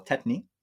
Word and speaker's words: tetany: And for the lobster claws, that's a phenomenon we call tetany. I'll spell it, T-E-T-A-N-Y, tetany, tetany.